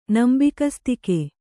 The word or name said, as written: ನಂಬಿಕಸ್ತಿಕೆ